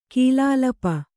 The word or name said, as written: ಕೀಲಾಲಪ